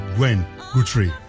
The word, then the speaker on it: Gwen Guthrie: È bellissima, entusiasmante, voce favolosa, forse un po' trascurata, è comunque difficile da pronunciare perché Gwen Guthrie, è giusto? – È giusto, buonasera.